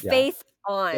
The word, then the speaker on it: Phaethon: Phaethon. Oh my god. – Think Phaethon. Like Phaethon.